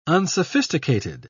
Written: unsophisticated

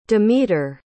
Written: demeter

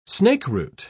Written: snakeroot

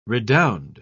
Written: redound

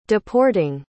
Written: deporting

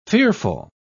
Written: fearful

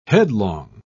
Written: headlong

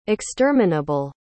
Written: exterminable

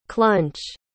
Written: clunch

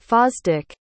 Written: Fosdick

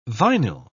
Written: vinyl

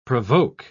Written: provoke

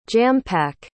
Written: jam-pack

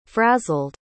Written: frazzled

